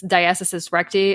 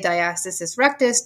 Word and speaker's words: diastasis recti: Yeah, there's several different ways you can say diastasis rectus, diastasis recti, diastasis, and I usually just call it DRA, diastasis.